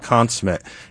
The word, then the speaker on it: consummate: It's pronounced consummate." And Rush Limbaugh said, "Look it up." And he shamed the guy. Now, today, you wouldn't get away with it because it's just by default consummate.